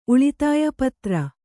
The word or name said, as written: ಪುತ್ರಿ